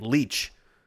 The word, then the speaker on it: Lich: That's the right way to say her name because I've heard her say it. Leach. Tamara Leach, spelled L-I-C-H. Some people spell it Leach, like L-E-A-C-H, which is the right way to say it, but another way to spell it.